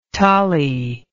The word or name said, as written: tolly